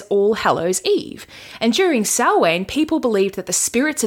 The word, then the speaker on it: Samhain: It's spelled Samhain for anyone who wants to Google it, but it's pronounced Samhain, which, like, that's Gaelic for you.